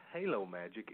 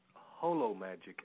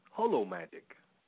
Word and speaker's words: Holo Magic: No, but it's not Halo Magic. It's Holo Magic, as in the Greek root holism and that kind of stuff. Holo Magic.